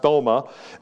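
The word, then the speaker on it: thoma (δῶμα): And then under that is doma, or it's pronounced thoma in the Greek, the roof of a house or the top of a house.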